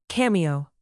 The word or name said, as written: cameo